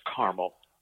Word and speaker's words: Carmel: Well, forgive me for correcting you, but in Indiana, it's pronounced Carmel. – Carmel. – Not like Carmel in California. It's Carmel like the candy.